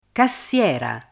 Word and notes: cassiera: Italian

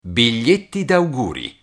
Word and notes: biglietti auguri: Italian

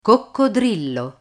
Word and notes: coccodrillo: Italian

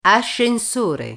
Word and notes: ascensore: Italian

